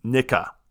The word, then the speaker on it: NIGCA: So this gun show is hosted by Nicker. N-I-G-C-A. Nicka is what it is. There's no R. I'm so sorry. No R. I butchered this.